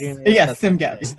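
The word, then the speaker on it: CSMGEP: That's how you say it. I didn't realize. – Yes, CIMGEP. – This is a part of the hidden curriculum, which is the acronym. – The way that the group pronounces the acronym. Because you never know from looking at it.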